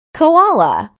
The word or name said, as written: koala